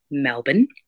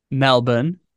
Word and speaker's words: Melbourne: Melbourne. – Melbourne. – Melbourne. – Melbourne.